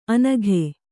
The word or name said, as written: ಅನಘೆ